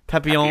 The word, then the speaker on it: Papillon: Papillon? Papillon? Is that how you say it? – Papillon. – Papillon, It, Hugo, and Wonder Woman, and Hot Fuzz are all above the notebook.